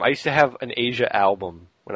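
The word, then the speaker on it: Asya: My favorite part of this email, as quirky as I am, at the end it says, sincerely, Asia, and it's A-S-Y-A, and then they put in parentheses, pronounced Asia like the continent or the band.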